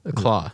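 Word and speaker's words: Klaue: It's Ulysses Clue? – Claw. – Claw? – Yeah.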